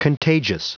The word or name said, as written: contagious